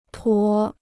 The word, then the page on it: 驮: duò